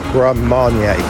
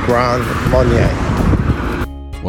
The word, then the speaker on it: Grand Marnier: It's pronounced Grand Marnier. It's pronounced again Grand Marnier. Well, I stand corrected, Kevin Curtis Allen. It's pronounced Grand Marnier.